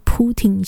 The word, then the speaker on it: Putin: 这个 Putin 先生，他的名字啊，他的姓是 Putin 哦，那个英文发音听起来有点像布丁，我不知道俄文怎么发啦，是不是也是一样啊？反正我也不会讲俄文哦，比较常听到还是用这种英文式的发音，或者说德文式的发音，随便是那个法国人就叫他布丹，布丹。